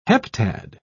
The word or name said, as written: heptad